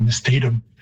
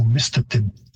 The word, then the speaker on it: Mistatum: so you get Mistatum. And the Cree stress or pitch system would have a higher pitch on the first syllable, so Mistatum.